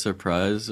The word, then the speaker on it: Surprise: pronounce surprise as surprise, or is it like, you know, East Palestine kind of thing? – Sorry, what? – Is it really pronounced Surprise, Arizona? Yeah. – It's pronounced Surprise.